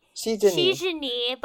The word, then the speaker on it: NCTzen: Their fandom name is Shijeni, but it's spelled NCTZEN, meaning like all the fans are citizens of NCT.